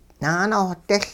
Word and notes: naanohdiL: hupa

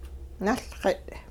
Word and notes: nuLxit': Hupa

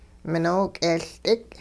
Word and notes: minook'eeLdik: hupa

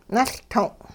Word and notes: nuLtong': Hupa

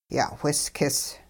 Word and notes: ya'whiskis: hupa

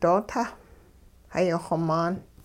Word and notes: doo-tah hayixw-maan: hupa

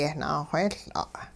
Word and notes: yehnaawheeyL'a': hupa

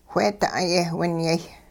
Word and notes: wheeda'ay-yehwinyay: hupa

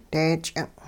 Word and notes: dee-ch'ing': hupa

